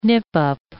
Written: nip-up

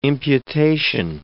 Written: imputation

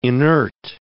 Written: inert

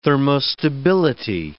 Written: thermostability

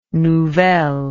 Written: nouvelles

